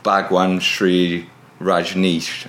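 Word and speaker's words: Bhagwan Shree Rajneesh: he's an enthusiast for Bhagwan Sri Rajneesh. Have I pronounced that right? – Yeah.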